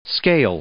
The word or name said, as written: scale